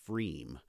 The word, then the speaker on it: Freiem: There's a brewery called Freem. It's spelled Freiem.